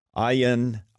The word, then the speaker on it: Ayaan: Ayan is a better way to pronounce that.